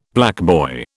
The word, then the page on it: blackboy: British English